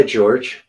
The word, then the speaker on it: Jorge: Well, no, he goes by George. – Oh, goes by George. Okay. – Yeah, it's spelled like Jorge, but his family's originally from Argentina, but he grew up here and he calls himself mm-hmm. George.